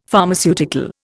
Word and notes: Pharmaceutical: Indian

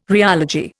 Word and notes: rheology: Indian

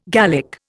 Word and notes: Gallic: Indian

